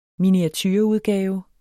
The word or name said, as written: miniatureudgave